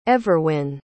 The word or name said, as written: everwin